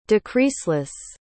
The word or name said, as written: decreaseless